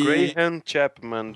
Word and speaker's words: Graham Chapman: Fiquei muito instigado a ver esse Cara, ele foi feito pelo Graham Chapman, não sei se essa é a pronúncia. – E... Graham Chapman, do Monty Python. Isso.